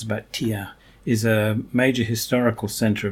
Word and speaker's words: Thiers: It's manufactured in an interesting city in central France called Thiers, that's how you pronounce it.